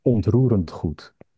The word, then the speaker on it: Ontroerend Goed: Maybe just to mention, as you said, you were struggling to pronounce the name of this theater group. It's Ontroerend Goed, which in Dutch means movingly good as well as real estate.